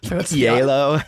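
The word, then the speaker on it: Hielo: What's Yalo? H-I-E-L-O, I believe. Yeah. I'm sure I nailed that pronunciation. – Yeah, absolutely. – Too. – Perfect. Beautiful accent.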